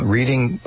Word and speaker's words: Reading: Well, it's pronounced Reading in PA. I don't know what it's pronounced in Ohio. Reading, Ohio.